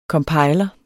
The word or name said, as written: compiler